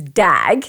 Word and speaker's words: dag: The word is spelt D-A-G, which basically in English is dag. So it's already like, what is this word in Dutch? – Yeah. – And we say dag.